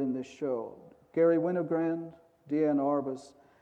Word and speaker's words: Diane: The other thing that I learned in the video, for 50 years I've been calling Arbus Diane, but In the video, her daughter, who should know how her name's pronounced, called her Deanne. So we'll try to stick to Deanne.